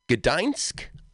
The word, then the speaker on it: Gdańsk: How do you pronounce Donsk? Oh, gee. Gdańsk? Donsk? Gdańsk? Marta completed her studies at the University of Technology Gdańsk, I think is how it's pronounced, in Poland.